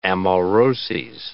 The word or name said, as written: amauroses